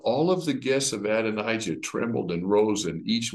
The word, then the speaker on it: Adonijah: Adonijah.